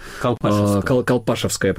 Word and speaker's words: Колпашевское: Колпашевское, правильное ударение.